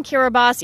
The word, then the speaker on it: Kiribati: So it's drowning Kiribati, even though it looks like it's written drowning Kiribati.